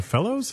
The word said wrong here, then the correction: Goodfellas: Sorry, Goodfellas. I'm from Jersey. – It's okay off the air, but on the air, you got to say it correctly. – Goodfellas.